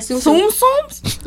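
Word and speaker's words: Tsum Tsum: Yeah, Tsum-Tsums. Like T-S-U-M. Yeah. They're pronounced Tsum-Tsum. – Yeah. – Like, you don't say the T.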